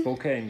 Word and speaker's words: Spokane: Du warst von 2010 bis 2012 auf der, ich weiß jetzt nicht genau, ob ich das richtig ausspreche, Gonzaga Universität in Spokane. – Spokane, genau, richtig, ja, da war ich.